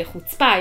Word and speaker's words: chutzpah: You hit the second syllable. – Chutzpah. – Chutzpah. There's a lot of air coming out of the mouth when you say that. – Why? So, when But in America, the accent's on the first syllable.